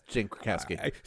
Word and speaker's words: Krakowski: Played by Jane, is it Krakowski? Is that how you say her name? – Sure. From 30 Rock. Yeah, that's Jane Krakowski.